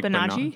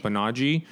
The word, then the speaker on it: Banaji: I'm going to butcher this, but it's Banaji. How would you pronounce that? I feel like Banaji. – Banaji.